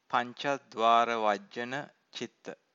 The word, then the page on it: pancadvaravajjana-citta: Pali